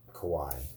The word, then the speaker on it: kawaii: It has like, a different, like, inflection on the... There's two I's, so it's kawaii. – Kawaii. – K-A-W-A-I-I.